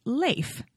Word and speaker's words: Leif: Or, as Leif Garrett explained in the beginning of a 2010 VH1 program, Leif Garrett Behind the Music Remastered, he himself says, for the record, it's Leif, long A. I'm still going to call him Leif.